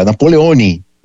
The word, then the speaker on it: Napoleone: E o Napoleão, né, vocês sabem, tinha sotaque, corso, e ele não era Napoleon ainda, era Napoleone, né, ainda tinha uma pronúncia italiana,